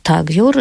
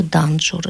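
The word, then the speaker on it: Тенгьюр: Это собрание Кагьюр, или, как в России часто произносили в монгольском произношении, Ганджур. И Тагьюр – это Данджур.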